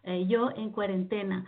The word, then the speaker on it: Yo en Cuarentena: Yeah, you pronounce it well. Yo en Cuarentena.